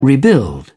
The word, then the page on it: rebuild: British English